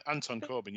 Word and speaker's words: Corbijn: Yeah, Anton Corbijn.